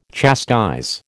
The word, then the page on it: chastise: American English